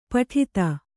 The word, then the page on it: ಪುಟಿತ: Kannada